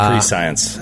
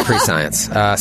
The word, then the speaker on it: prescient: Not unlike Callum, you are quite the prescient character. You saw that all happening before it happened. – It's pronounced pre-science. – I'm sorry, it's pre-science.